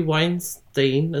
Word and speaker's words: Weinstein: I mean, you just look at, like, the Harvey Weinstein.